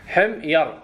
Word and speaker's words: حمير: حمير على وزن درهم، واضح إن شاء الله.